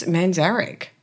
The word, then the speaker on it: Manzarek: But this is where he met Ray Manzarek. – No, it's Manzarek with an R.